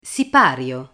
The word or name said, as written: sipario